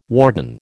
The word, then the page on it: warden: American English